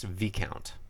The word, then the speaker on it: viscount: Okay, number one, I'm pretty sure it's pronounced Viscount. – No, it's a... – The S is silent.